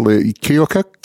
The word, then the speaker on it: Keokuk: Well, Keokuk.